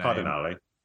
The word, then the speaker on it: Cardinale: You got Claudia Cardinale. Sorry if I butchered her name. – Cardinale.